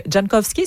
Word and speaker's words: Jankowski: mais aucun n'a été repêché aussitôt que Mark Jankowski, est-ce que je le prononce comme exactement.